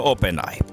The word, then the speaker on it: OpenAI: Mun mielestä meidän pitäisi yhteisesti kaikkien siirtyä niin kuin tästä OpenAI-puhumisesta, että lausutaan se ihan niin kuin suomalaisittain, kuten se kirjoitetaan, eli OpenAI. Tiedätkö, että mitä Ope tekee? No OpenAI.